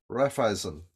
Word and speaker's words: Raiffeisen: Did I pronounce that correctly? – Raiffeisen, that's the one, Raiffeisen Bank.